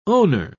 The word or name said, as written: owner